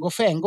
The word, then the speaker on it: Gauffin: Skulle du säga Gauffin eller Gauffin? Gauffin är väl det försvenskade uttalet skulle jag tro. – Ja, Gauffin är, man vill gärna säga Gauffin instinktivt, men Gauffin är den försvenskade versionen om jag uppfattat det korrekt.